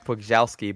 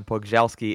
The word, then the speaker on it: Pogorzelski: It's Kev Pugzelski, but if you're Googling it, it'll be Kevin, and then Pugzelski is spelt like Pogorzelski, right? – It is, absolutely.